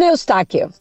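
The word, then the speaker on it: Eustáquio: ¿Cómo se pronuncia esto, Eustaquio? – Stephen Eustaquio. Minuto 90 más 2 de agregado.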